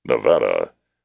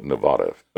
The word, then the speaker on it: Nevada: The next toughest state to pronounce was Nevada, because people can't stop saying Nevada instead of the preferred Nevada. It's not Nevada, Nevada, right? It's Nevada, not Nevada.